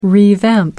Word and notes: revamp: American English

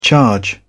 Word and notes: charge: British English

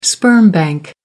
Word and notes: sperm bank: American English

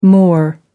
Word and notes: moor: American English

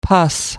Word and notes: pus: American English

